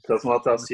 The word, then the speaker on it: Cosmatos: This is another thing that that Panos I don't know how to say his name right. Panos Cosmatos or Panos Cosmatos, yeah.